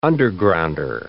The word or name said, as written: undergrounder